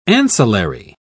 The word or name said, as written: ancillary